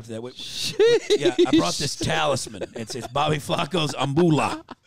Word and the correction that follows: abuelo: Is that how you say it? – Abuelo, bro.